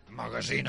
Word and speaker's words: magazín: Magazín, es diu magazín.